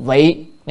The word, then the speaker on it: vị: Rồi vị cầu pháp cố là vì cầu pháp cho nên, tức là vì, chứ này đọc là vi hay vị, nhưng mà chỗ này phải đọc âm vị nha.